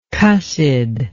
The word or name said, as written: cussed